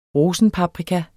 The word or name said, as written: ROSENPAPRIKA